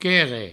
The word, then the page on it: querre: provençal